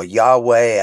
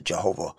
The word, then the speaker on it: Yahweh: That's when you see the word Lord, all caps in a King James version, that's representing the word that some people call Yahweh. Other people pronounce it Jehovah.